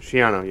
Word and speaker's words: Schiano: Schiano. Yeah for pronunciation. – Schiano, yes.